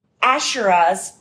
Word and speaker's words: Asherahs: built them Asherahs, right? Am I pronouncing? Yeah.